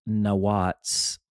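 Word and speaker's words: nawats (נאץ): It's interesting how the Hebrew word pronounced nawats behind the translated English word contempt means to blaspheme.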